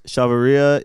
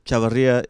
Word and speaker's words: Chavarria: Chavarria is the white people pronunciation. Chavarria is the Latin pronunciation. Chavarria is the Japanese pronunciation.